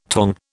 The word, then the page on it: tong: British English